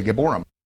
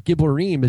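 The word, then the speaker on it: Giborim (גיבורים): So obviously he's not studied Hebrew because Geborim, you know, the way he's pronouncing it, first of all, Geborim is plural. And so it says he began to be a Gibor before the Lord, a mighty hunter. And Giborim is used throughout the Tanakh.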